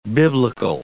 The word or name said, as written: biblical